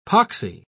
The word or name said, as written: poxy